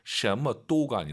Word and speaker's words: 什么都: 발음 잘하시기 바랍니다. 什么都가 아니라 什么都, 什么都.